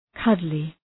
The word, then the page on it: cuddly: ‘kʌdlı